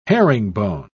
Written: herringbone

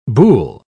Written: boule

